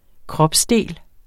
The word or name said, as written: kropsdel